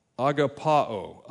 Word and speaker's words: agapao: Let me make sure I pronounce it right. Agapao, agapao. And we would think it's agape or agape is really, I think, the correct way to say that, by the way. This is the verb form. Agape would be the noun. Agapao is the verb, and verb, if we got any English teachers in here, I know there's one because it's my mom, they know grammar.